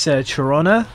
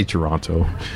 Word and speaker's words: Toronto: We were told like the second T means you're tourist, so you don't pronounce the second T. – Oh, really? I say Toronto.